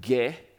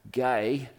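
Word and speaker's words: gay (γῆ): and land in Greek is the word ge, or it's actually pronounced gay, but we look at it as ge, geography, geology.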